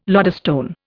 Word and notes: lodestone: Indian

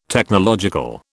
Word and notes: Technological: British English

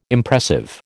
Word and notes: Impressive: American English

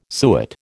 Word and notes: suet: American English

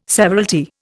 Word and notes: severalty: Indian

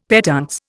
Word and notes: petuntse: Indian

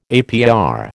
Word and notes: APR: American English

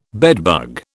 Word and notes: bedbug: British English

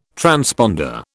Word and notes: transponder: British English

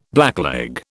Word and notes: blackleg: British English

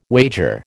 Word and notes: wager: American English

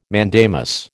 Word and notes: mandamus: American English

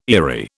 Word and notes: eyrie: British English

